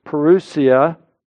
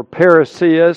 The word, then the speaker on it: parousia: Well, the Greek word that Peter uses is parousia, or parousia, some people pronounce it.